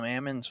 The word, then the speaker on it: almonds: I know that there's a difference in how you say it, but almonds is just easier to say. – Mm-hmm. But anyways, you shake the L out of it, right? – Yeah, you shake the L out of it.